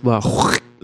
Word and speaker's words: La Croix: It's pronounced La Croix. It's French. You pronounce it La Croix in the throat there.